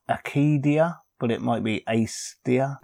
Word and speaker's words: Acedia: But I'm assuming I would want to go with a soft C. – Acedia. – Yeah, it is Acedia. I just checked.